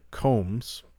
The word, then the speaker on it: Combs: Jeffrey Combs, although it looks like Combs, but I believe it's pronounced Combs, pretty sure.